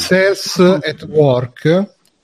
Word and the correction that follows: Cells at Work: Uh, vedo se l'ho detto bene. Sì, Cells at Work, che è quell'anime che è uscito di recente sul.